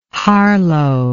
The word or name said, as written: Harlow